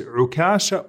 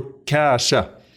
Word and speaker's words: Ukasha: So his name is Ukasha or Ukasha with a shadda on the kaf. Both of them are ways that his name is pronounced.